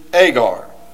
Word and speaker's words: Agar: For these are the two covenants, the one from the Mount Sinai, which gendereth to bondage, which is Agar." New Testament pronunciation of Hagar.